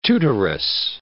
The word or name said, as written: tutoress